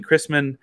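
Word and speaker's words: Christman: One guy transferring out of Ohio State is a guy named Ben Chrisman, spelled like Christmas, but Chrisman.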